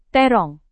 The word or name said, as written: terong